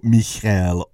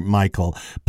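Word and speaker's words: Michal: I was raised a low-church Protestant, and we always pronounced her name Michael. I realize in the Hebrew it's Michelle, and I realize that that probably is the preferred pronunciation of her name, but I can't help myself. My Baptist roots are showing through,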